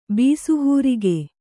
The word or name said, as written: ಬಿಸುಹೂರಿಗೆ